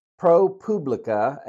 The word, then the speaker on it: ProPublica: So recently, ProPublica, and I pronounced it Publica in a previous episode, and apparently it's ProPublica. That's what I've been told. That's what I checked and double checked, and they said Publica. So that's how I'll pronounce it. It's a PRO. Capital PUBLICA, and it's all crammed together.